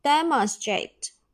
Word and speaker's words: demonstrate: 现在分词 demonstrate，把一去掉，再加 ing。第三人称单数 demonstrate，直接在后面加一个 s 就可以了。那么发音呢，我们重点啊要小心它这一个啊 ch 音要变成 j 音去读哈，因为前面有一个死音啊，所以呢它是读成 demonstrate，demonstrate，而不是 demonstrate 啊，这一个要小心。下面我们重点来说一下这个单词的记忆方法。